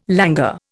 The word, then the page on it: languor: Indian